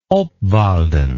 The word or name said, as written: obwalden